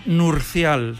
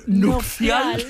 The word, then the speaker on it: nupcial: Sí, es que yo digo que hasta los 13 años pensé que era nurcial. – Nurcial. – ¿Sí? Y claro, de me cuesta decir nupcial, que lo he dicho mal. – Has dicho nupcial. – He dicho nupcial. Nupcial, sí, sí.